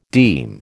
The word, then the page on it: deem: American English